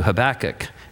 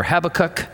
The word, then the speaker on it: Habakkuk: And you can pronounce it however you like, Habakkuk or Habakkuk, because no one knows how it was pronounced, and he's not here to tell us. It's an Akkadian loan word, and we don't know how to pronounce it. So I say Habakkuk, and there you have it.